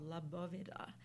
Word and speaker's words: La Bóveda: intriguing piece to me called La Bóveda. – Mm-hmm. – Did I pronounce that okay? – Yes, you did.